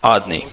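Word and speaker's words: Adni (אדני): It is pronounced as it is written, Adni.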